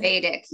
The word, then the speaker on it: Vedic: Vedic, yes.